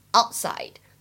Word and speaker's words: outside: 再来看一下 outside 这个单词当中呢，我们的 t 有不完全失去爆破 outside outside。